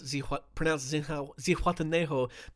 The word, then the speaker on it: Zihuatanejo: Now, I only know how to pronounce Zihuatanejo because it's the place in The Shawshank Redemption where Andy Dufresne is like, "Come and meet me, man, in Zihuatanejo."